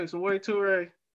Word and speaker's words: Samori Toure: Samori, is how you say Samori Toure? – Yep, that's exactly right.